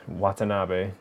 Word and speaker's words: Watanabe: And with like Ken Watanabe. Why did I say his name like that? That was weird. Watanabe.